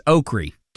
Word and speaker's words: okra: We're talking about the vegetable that you fry up, O-K-R-A. How do you pronounce that? My family says okra. Can you bring some fried okra? Can we get fried okra when we go to the barbecue place? And if your family says it like that, or you know somebody that says it like that, find me at Tug Cowart. I'd love to hear from you because I'm catching a heck for pronouncing it that way.